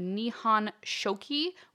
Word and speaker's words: Nihon Shoki: So the main stories about Toyotama Hime are told in the Kojiki, which is the Record of Ancient Matters, and the Nihon Shoki, which is known as the Chronicles of Japan. – Super proud of your pronunciation there.